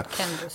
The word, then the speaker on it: Candace: Следующая девушка, корреспондентка Fox News, как же она читается, как вы думаете, Candace Owens, Candace, или не Candace, точно, да. – Candace.